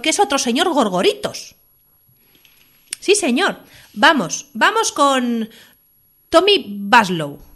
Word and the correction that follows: Bastow: O Bastow. No lo veo bien. – Mira, se escribe Bastow.